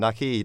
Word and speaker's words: Naqi: Naqi, that's the way of pronouncing. Naqi, it's like hockey except with an N in front.